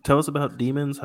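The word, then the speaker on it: Deamins: Let's talk about your group, The Demons. Now, oh, sure. – For those who want to look it up, it's spelled a little differently. It's D-E-A-M-I-N-S.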